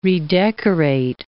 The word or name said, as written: redecorate